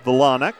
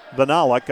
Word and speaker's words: Vinalak: The accent on the second syllable there, Vinalak. Vinalak, I should say.